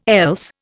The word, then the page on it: else: Indian